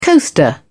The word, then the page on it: coaster: British English